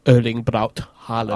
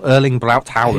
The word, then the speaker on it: Erling Braut Haaland: Erling Braut Haaland. – Ah, excellent. I knew you'd be able to pronounce it properly as well. Erling Braut Haaland.